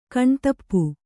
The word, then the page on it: ಕಣ್ತಪ್ಪು: kaṇtappu